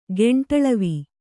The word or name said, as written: ಗೆಂಟಳವಿ